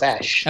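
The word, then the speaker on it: Æ: He says it's pronounced Ash.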